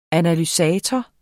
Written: analysator

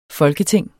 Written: FOLKETINGENE

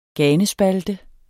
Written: ganespalte

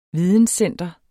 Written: videnscenter

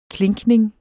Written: klinkning

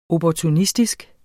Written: opportunistisk

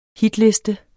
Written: hitliste

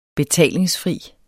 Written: betalingsfri

